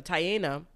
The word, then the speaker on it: Taina: Ari like gave, you know, the babies to G Herbo, but G Herbo left and left the baby with Taina. Mm. Is that how you say her name? Mm-hmm. Whatever, Taina.